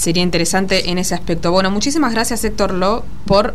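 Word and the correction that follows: Lew: ¿Héctor Lu o Héctor Lo? No sé. ¿Cómo se pronuncia tu apellido? – Leu. – O sea, Leu. Bien. L-E-W. O sea, no lo dije bien ninguna de las dos veces.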